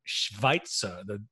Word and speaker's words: Schweitzer: So he chooses his name as Dr. Schweitzer, which first of all, if you're going to pronounce that in German, that it's not the W sound. It's going to be Schweitzer. – Schweitzer. – The W is a V, right?